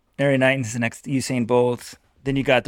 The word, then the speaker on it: Usain: We had a caller actually call in and they commended you on the pronunciation. It is Usain Bolt.